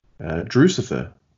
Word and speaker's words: Drucifer: And Andy's over there on the tweets as well as Drucifer tweets. So, you know, rhymes with Lucifer, you'll do all right.